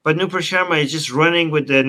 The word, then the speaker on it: Nupur Sharma: But it's also embarrassing for Nupur Sharma.